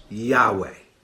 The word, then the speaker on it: Yahweh: Y, H, W, H. Okay? Sometimes in older, it's Y, H, V, H. That we pronounce Yahweh.